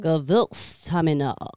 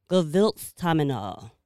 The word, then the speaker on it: Gewürztraminer: Gewürztraminer. – Say it one more time. – Gewürztraminer.